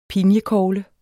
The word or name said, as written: pinjekogle